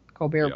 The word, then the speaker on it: Colbert: Colbert will be poor. – We are. – The T is silent.